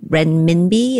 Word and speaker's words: renminbi: And when it comes to currency status, I guess my question is, where does the Chinese currency, the renminbi, is that the right pronunciation, right? – Yep.